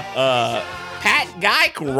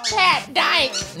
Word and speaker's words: Geick: Y'all, he's, as the old gal would say, Pat Geick rhymes with Dyke. Yes, Big Fatty, y'all, it's Pat Geick. Yes, Big Fatty, we love Pat Dyke. No, Geick.